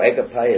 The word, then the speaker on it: agape: Just quickly, if we go to Hebrews chapter 12 and verse 6, this love, this agape that we speak about, or agape as some pronounce it, verse 6.